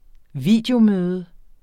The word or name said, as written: videomøde